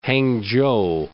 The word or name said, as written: Hangchou